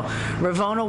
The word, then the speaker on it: Ravona: So Ravona White Magic is my pseudonym. – How do you spell that? – R-A-V-O-N-A.